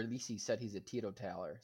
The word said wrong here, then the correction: teetotaler: so he doesn't drink, if that's how you pronounce it, Tito Taylor. – Teetotaler. – Teetotaler, okay. Teetotaler, that's good to know.